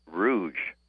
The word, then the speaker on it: Rouge: Hey, listen, I was listening to your article or little thing on Henry Ford there, and wanted to tell you that that plant is pronounced Rouge, okay, like the river that's right there, Rouge River.